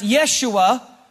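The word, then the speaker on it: Yeshua (ישוע): Because Joshua, interestingly enough, in Hebrew is pronounced Yeshua, which is translated Jesus.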